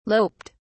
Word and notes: loped: British English

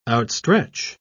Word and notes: outstretch: British English

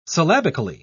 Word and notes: syllabically: British English

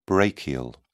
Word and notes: brachial: British English

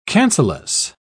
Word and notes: cancellous: British English

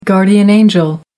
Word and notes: guardian angel: American English

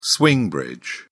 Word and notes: swing bridge: British English

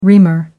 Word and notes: reamer: American English

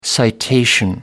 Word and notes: citation: American English

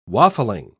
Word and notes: waffling: British English

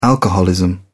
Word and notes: alcoholism: British English

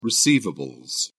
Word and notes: receivables: British English

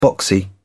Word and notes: boxy: British English

